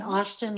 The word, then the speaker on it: Austin: And Austin like the Austin car or Steve Austin, the $6 million man.